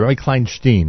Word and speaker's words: Rami Kleinstein: why do I keep saying that? Rami Kleinstein, right? Isn't that a better way to pronounce it?